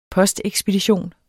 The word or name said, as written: postekspedition